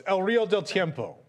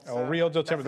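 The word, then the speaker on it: El Río del Tiempo: El River de Tiempo. – I'm gonna give it to you. It's El Rio del Tiempo. El Rio del Tiempo.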